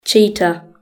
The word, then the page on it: cheetah: British English